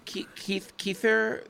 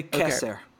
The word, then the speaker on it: Keser: We're going to say Keser. Okay. Keser makes it so much easier. Keser. Because you're going to do the more Eastern European pronunciation.